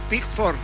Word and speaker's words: Pickford: Jordan Pickford será el arquero.